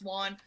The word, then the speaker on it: Won: My last name's pronounced Juan.